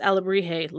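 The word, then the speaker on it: alebrije: Alabrije. Yes, Alabrije, like he turns into at the end of the movie.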